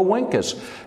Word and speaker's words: vinces: In hoc signo vinces, and that's classical Latin pronunciation for vincus. In this sign conquer, in hoc signo vinces.